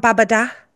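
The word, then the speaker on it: Babadağ: In the background, we have Mount Babada. – Mount Babada. – But it's spelled Babadag. – Babada.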